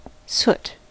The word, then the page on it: soot: American English